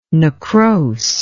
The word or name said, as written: necrose